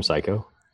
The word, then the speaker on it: Cayko: So one of our fellow podcast friends with Inside the Firm, Lance Psycho, he's awesome. – Is his last name Psycho? – Yeah, but it's spelled differently. – Don't care. That's rad. – C-A-Y-K-O.